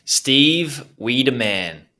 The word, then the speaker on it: Steve Wiideman: Steve Wiideman.